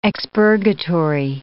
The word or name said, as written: expurgatory